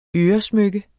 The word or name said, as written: øresmykke